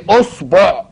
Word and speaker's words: إصبع: في نطقها بيبقى الناس يعني تحتار، هنقولها أصبع ولا إصبع ولا أصبع ولا، قل له انطقها كما شئت ما دمت تسكن الباء، ما دمت تسكن الصاد، سكن الصاد وانطقها على كيفك.